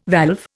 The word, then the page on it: valve: Indian